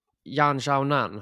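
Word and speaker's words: Jan Xiaonan (闫晓楠): Alltså Jaina, jag har hört det uttalas. Jan Xiaonan har jag hört det uttalas. – Det kan så X-et är Xiaonan.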